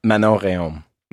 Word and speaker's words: Manon Rhéaume: M- Manon Rhéaume.